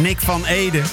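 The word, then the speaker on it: Nick van Eeden: Nick van Eden. Nou ja, je zal het in op zijn Engels moeten uitspreken. Nick van Eden, de zanger van de band Cutting Crew.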